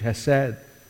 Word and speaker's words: hesed: The Hebrew word for this steadfast love is the Hebrew word pronounced in English, hesed.